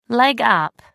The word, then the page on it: leg up: American English